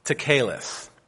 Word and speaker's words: tekelet: The word is spelled T-E-K-E-L-E-T, and it's pronounced tekeleth.